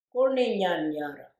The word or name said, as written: Goorninyan yarre